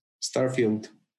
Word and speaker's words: Starfield: Starfield.